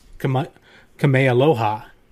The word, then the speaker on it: Kamealoha: It is Doogie Kamealoha MD.